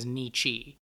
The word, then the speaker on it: Nietzsche: It's based on a novel of the same name by the philosopher Friedrich Nietzsche, although you may have heard his name mispronounced as Nietzsche.